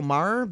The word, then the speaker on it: Maher: Bill Maher? – Bill Maher, the Bill comedian.